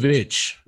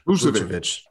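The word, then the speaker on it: Vučević: Tell me, how do you pronounce it? Vucevic. – Vucevic. Thank you.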